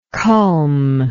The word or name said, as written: culm